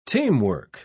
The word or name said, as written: teamwork